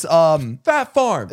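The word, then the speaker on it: Phat Farm: Fat, no, you got the name wrong. Fat Farm. It's Fat Farm. – It's Fat Farm? – P-H-A-T. Fat Farm. Oh. And it had that P with like the sound.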